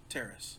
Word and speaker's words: Taris: So you pronounce it Taris? – Taris.